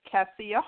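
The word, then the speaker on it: Kassia: So on Facebook, it is actually just my name as Cassia Hart, and Cassia spelled K-A-S as in Sam, I-A, last name Hart.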